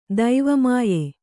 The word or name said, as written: ದೈವಮಾಯೆ